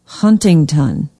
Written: huntington

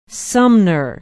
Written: sumner

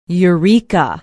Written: Eureka

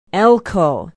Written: elcho

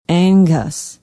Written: angus

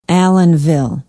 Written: allenville

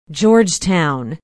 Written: Georgetown

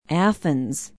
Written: athens

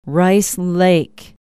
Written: rice lake

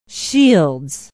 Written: shields

